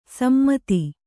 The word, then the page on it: ಸಮ್ಮತಿ: sammati